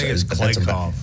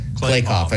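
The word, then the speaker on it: Kliavkoff: George Kalikoff, I think it's Kalikoff. – Kalikoff, okay, but George Kalikoff.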